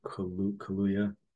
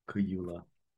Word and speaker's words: Kaluuya: Daniel Kaluuya. – Yeah, that him. – Kaluuya.